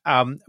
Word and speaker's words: Elam: So Elam, are you going to pronounce it Elam or something? – No, Elam is good. Yeah. – Elam.